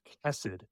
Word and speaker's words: chesed: It's pronounced chesed.